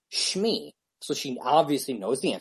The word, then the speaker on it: Shmi: They wanted to know the first name of Anakin's mother, and her name is Shmi, and it's spelled S-H-M-I, and Janine spelled it wrong. She spelled it like S-C-H-M-E-E, I think, something like that. But phonetically, that's correct. You know, if you're saying it, Shmi, you know, so you take that word and you say that word, it's said correctly.